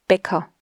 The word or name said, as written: Baecker